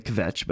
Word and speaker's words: kvetch: I don't think that the K is silent. I think it's actually kvetch, but it's K-V-E-T-C-H.